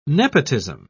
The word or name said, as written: nepotism